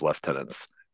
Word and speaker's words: lieutenant: So it's a British, it's just how British, it's like an old British pronunciation of lieutenant, because the U was more of a V sound.